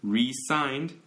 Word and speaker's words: re-signed: Z 발음으로 하면은 사직한다 이런 의미고 S 발음으로 하면은 이렇게 그 이거 재계약. So he re-signed S 발음으로 했잖아요.